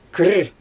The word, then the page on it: kiir: Xyzyl